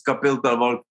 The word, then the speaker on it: Kapil Talwarkar: Yeah, I mean, I go like Cap Talwarkar, okay? So, but yes, it's Kapil Talwarkar.